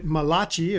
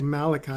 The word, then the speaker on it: Malachi: If you wouldn't mind making your way to the book of Malachi, Malachi chapter 3, Malachi, that prophet, also known as the Italian prophet Malachi, if you want to pronounce it that way, but it's actually closer to the Hebrew to say Malachi.